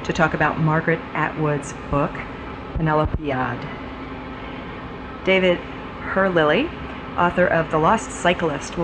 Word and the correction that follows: Herlihy: I'm not sure I said his name right. It's H-E-R-L-I-H-Y. Herlihy.